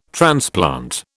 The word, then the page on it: transplant: British English